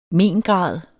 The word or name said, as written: mengrad